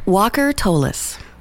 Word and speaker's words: Walker Tolles: Walker Tolis.